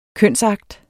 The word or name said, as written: kønsakt